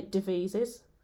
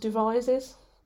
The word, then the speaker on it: Devizes: House of, I should controversially say, I say Devizes. There's a whole story behind this, by the way. But it's actually pronounced Devizes.